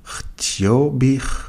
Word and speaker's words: htio bih: Htio bih, htjela bih. Das H wird wie ein H ausgesprochen im Kroatischen.